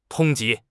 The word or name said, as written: 通缉